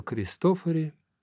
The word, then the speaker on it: Кристофори: Бартоломео де Франческо Кристофори ударение всегда на первом «о», родился в Падуе 4 мая 1655 года, а умер во Флоренции 27 января 1731 года.